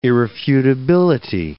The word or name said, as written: irrefutability